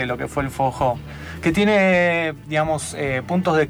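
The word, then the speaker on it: forró: ¿Me repite el nombre del género? – Fojó. – Fojó. Así sí. Está bien pronunciado.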